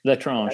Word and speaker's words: L'Étrange: We were able to, you know, go to L'Étrange. Am I saying that right? – L'Étrange, yeah.